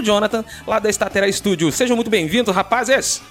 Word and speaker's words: Statera: Estatera ou Estatera? Tem alguma forma correta de pronunciar? Eu estou pronunciando errado? É Estatera.